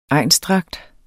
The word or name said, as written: egnsdragt